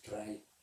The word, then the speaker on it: Raye: Hay un artista que se llama Rai, o Raye, como lo que si lo españolizamos sería Raye, pero bueno, me he visto un vídeo hasta de cómo se pronuncia, pero es Rai.